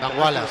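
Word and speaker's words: Kambwala: ¿Y a Foy lo va a dejar del central o cómo se dice? Cambulá, ¿no? También y todo Cambala, sí.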